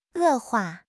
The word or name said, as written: 恶化